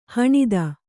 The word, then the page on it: ಹಣತಿ: haṇati